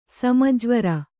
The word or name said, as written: ಸಮಜ್ವರ